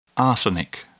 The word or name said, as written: arsenic